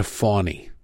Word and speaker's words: Fani: No, Fawny has a W in it. Fanny is spelled F-A-N-I. Her name is F-A-N-I, right? This isn't a Kiev situation where we just decided to pronounce it different. There's no W there. There's no world in which F-A-N-I has a silent W in it. Well, it's pronounced with a W.